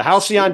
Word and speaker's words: halcyon: The halcyon days of 3 years ago when Lonzo was last healthy. That's where we're talking about. – That's how you pronounce that word.